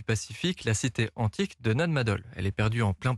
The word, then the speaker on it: Nan Madol: Et vous allez parler ce matin de la cité antique de Nan Madol. C'est comme ça qu'on prononce? – Oui, c'est bien Nan Madol.